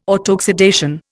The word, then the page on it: autoxidation: Indian